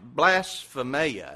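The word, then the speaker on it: blasphemia (βλασφημία): Actually, it's pronounced blasphemia is what it's pronounced.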